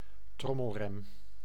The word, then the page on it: trommelrem: IPA: /ˈtrɔməlˌrɛm/